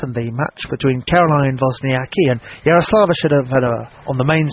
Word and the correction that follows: Shvedova: stadium court, I'll let Russell Fuller pronounce that correctly. – Well, I don't need to pronounce her more than one more time, Yaroslava Shvedova, because she's just been beaten by Caroline Wozniacki after quite a tight match.